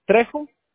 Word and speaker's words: Trejo: Trejo. – Okay. Is that T-R-E-I-J-O? – Oh, it's a T-R-E-J-O.